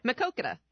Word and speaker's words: Maquoketa: Maquoketa.